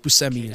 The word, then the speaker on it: Buscemi: I totally related to Steve Buscemi in this, or Steve Buscemi, as I think he pronounces his name,